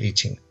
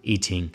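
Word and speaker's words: Yijing: Entonces, los caracteres Yi, Qing, para que suene lo más parecido a una a lo que es el carácter chino, se escribe ahora actualmente Yi Jing, pero la pronunciación es la que conocemos de siempre, Yi Jing.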